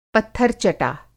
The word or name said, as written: पत्थर-चटा